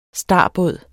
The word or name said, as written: star